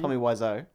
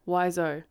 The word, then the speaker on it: Wiseau: Tommy Wiseau. – Wiseau.